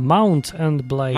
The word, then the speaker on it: Mount and Blade: Mountain Blade, ogniem i miecze. – Mountain Blade? Co górskie ostrze? A Mount and Blade.